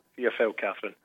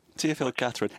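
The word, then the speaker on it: Théophile-Catherine: Can you give us the definitive pronunciation of his name, first of all? – Théophile Catherine. – Oh, that's much easier. Théophile Catherine.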